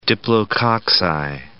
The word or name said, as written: diplococci